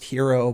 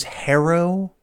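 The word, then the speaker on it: Herro: I would like to point out the fact that I know his name is technically pronounced Hero, but before he got to college, he was originally at Wisconsin, it was Harrow, and this Hero thing has been the change over the last couple of years.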